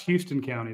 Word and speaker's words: Houston: Wait, no, it's pronounced Houston County, don't you know? – No.